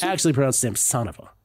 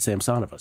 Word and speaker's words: Samsonova: I thought the accent was always on the O before the OVA, you know, on the OVA. But apparently they pronounce it Samsonova.